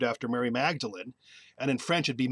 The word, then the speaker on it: Magdalen: But again, the tricky part of English and in any language really is down the street is a Magdalen Chapel, spelled the same way. and pronounced Magdalene.